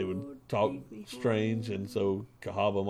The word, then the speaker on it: Cahawba: Cahaba. – But it still says, it's still pronounced Cahaba. So the city is spelled C-A-H-A-W-B-A. So they throw a W in there.